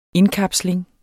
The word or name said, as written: indkapsling